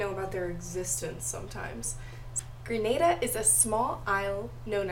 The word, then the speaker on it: Grenada: Not Grenada, but Grenada.